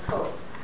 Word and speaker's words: Koch: by the name of Koch. Yeah, well, you pronounce it like, yes, not just like the drink, but it's spelled K-O-C-H.